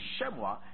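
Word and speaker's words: shemuah: Shemua. Shemua is spelled S-H-E-M-U-A-H. S-H-E-M-U-A-H.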